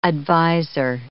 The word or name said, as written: advisor